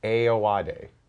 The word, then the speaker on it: Ayoade: Um, I'm going to slaughter his name. Tom, my producer, told me how to say it. Richard, I, I Moss from IT I-O-J. Crowd. – Ayoade. Richard Ayoade.